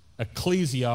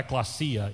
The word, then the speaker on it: ecclesia: If you study the etymology of the word for church, which is ecclesia or ekklesia, either way you want to pronounce that, then it means this.